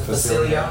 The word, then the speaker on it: Facilier: That is Dr. Facilier. How do you pronounce it? – Facilier.